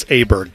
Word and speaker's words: Åberg: I didn't realize it's Åberg, but it doesn't look like that.